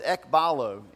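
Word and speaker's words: ekballo: But there's another word that he uses here, and the word is ekbalo. If you're taking notes, you want to spell it. It's E-K-B-A-L-L-O. Ekbalo. It's a fun word to say.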